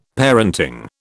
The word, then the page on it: parenting: British English